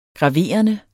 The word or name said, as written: graverende